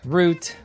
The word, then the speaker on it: Route: And today we're talking about Season 7, Episode 13, titled The Milk and Honey Route. – Root. – Are you going to correct my grammar like Don? – Damn straight I am. – It's more pronunciation correction, but okay.